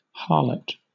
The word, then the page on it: harlot: British English